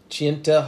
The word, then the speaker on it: Chena: Chienta Hot Springs in Alaska. It's spelled C-H-E-N-A.